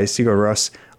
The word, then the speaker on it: Sigur Rós: And closing out this hour, the bottom 10 is Stormur by Sigur Rós off of the new album Kvikr at number 11, up from 17. – Again, I am impressed by your pronunciation.